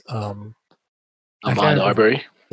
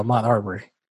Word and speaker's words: Ahmaud Arbery: Ahmaud Arbery. – There you go, Ahmaud Arbery.